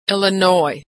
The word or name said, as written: Illinois